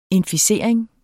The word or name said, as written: INFICERING